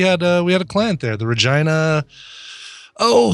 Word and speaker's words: Regina: It is pronounced the Regina. Yeah. Saskatchewan. Yeah, I don't want to live in Regina. I want to live somewhere that doesn't rhyme with vagina.